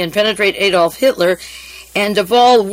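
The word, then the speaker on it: de Wohl: And William Stevenson sent out a man named Louis de Wahl, D-E, it's pronounced de Wahl, but it's spelled W-H-L, who came to the United States in 1941.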